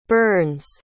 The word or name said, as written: Byrnes